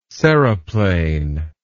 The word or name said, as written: seriplane